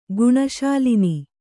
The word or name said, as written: ಗುಣಶಾಲಿನಿ